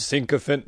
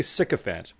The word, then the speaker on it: sycophant: Yeah, it's actually sycophant, but that's quite all right.